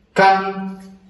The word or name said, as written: kan